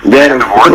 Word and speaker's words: Vandervoort: And so, you know, one of the stars, it's actually the story of Donovan Turner, who's played by Laura Vandermoot. Am I pronouncing that right? – Vandervoort. – Okay, it's a hard one there.